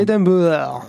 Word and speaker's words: Edinburgh: Alors, point prononciation, on ne prononce pas le G dans Edinburgh. Ah oui, c'est Edinburgh. Edinburgh.